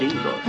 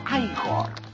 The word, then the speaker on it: Igor: Frankenstein, usted debe de ser Igor. – No, se pronuncia Igor.